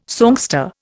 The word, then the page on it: songster: Indian